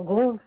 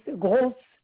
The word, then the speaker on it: Gross: Alors, grosse, GROSS. Daniel. – Le nom s'écrit comment? – GROSS, grosse.